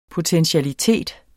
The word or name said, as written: potentialitet